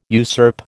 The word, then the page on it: usurp: American English